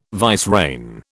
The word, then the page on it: vicereine: British English